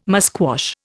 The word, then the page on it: musquash: Indian